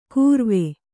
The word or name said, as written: ಗುರ್ವೆ